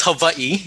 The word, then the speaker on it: Hawaii: it's Hawaii. I mean, that's the proper way to say it. – Oh, is it? – Yeah. Yeah. So because they use, or they sound the W as a V, because V is not a letter in the Hawaiian alphabet.